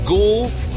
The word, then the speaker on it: gochujang: Okay, three easy syllables. Gochujang. It's G-O-C-H-U-J-A-N-G.